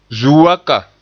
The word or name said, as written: zuu waka